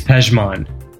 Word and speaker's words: Pejman: Well, I didn't know I was going to be like, is this like a Silent J situation? – Right. No, that's a valid assumption. – Because what's it? P-E-J-M-A-N.